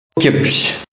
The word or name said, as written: mouton